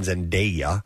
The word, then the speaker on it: Zendaya: A new study has found that Zendaya, by the way, that's how you pronounce her name, Zendaya, is the most in-demand actor in the United States and worldwide.